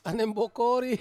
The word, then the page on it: aneng bokori: Ansus